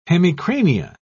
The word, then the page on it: hemicrania: British English